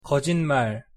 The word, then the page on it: 거짓말: Korean